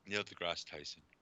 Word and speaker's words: Neil deGrasse Tyson: Neil deGrasse Tyson.